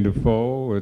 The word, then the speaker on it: Dufault: My name is Peter Kane Dufault. It's spelled D-U-F-A-U-L-T as in default, but it's a French name, French-Canadian.